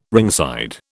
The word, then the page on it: ringside: British English